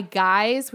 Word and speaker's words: Guize: So it's this kit by Guys, which is spelled like G-U-I-Z-E.